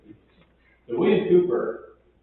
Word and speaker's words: Cowper: There was a man named William, and if you want to sound really smart when you talk to people about this, pronounce his name Cooper, and they'll say, no, it's Cowper, and you can say, no, it isn't Cooper. Because it really is Cooper.